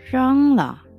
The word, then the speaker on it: 扔: 중국어 발음할 때 예, R로 시작하는 핑음이 있어요. 그러면 wrong 이거는 영어의 R 사운드하고는 좀 달라요. 죄송합니다. 그래서 어, 간콰이 렁러 간콰이 렁러 그래서 빨리 렁 버려.